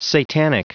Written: satanic

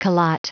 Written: calotte

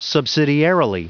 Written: subsidiarily

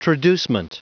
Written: traducement

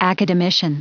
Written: academician

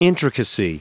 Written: intricacy

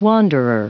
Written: wanderer

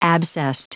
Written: abscessed

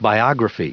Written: biography

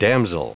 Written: damsel

